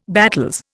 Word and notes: battels: Indian

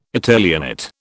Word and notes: Italianate: British English

